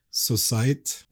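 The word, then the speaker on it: Société: I think it's spelled Society. I mean, not spelled said Society, but spelled like Societe.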